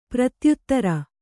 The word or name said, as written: ಪ್ರತ್ಯುತ್ತರ